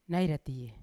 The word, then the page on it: nai ratie: Yawa